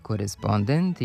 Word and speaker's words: korespondent: recipijent i korespondent imaju kratko uzlazni akcenat na pretposlednjem slogu, jer prema pravilima naše akcentuacije poslednji slog ne može biti akcentovan. N